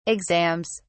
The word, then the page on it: exams: British English